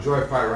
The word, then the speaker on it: joyify: Now, joyify rhymes with hi-fi.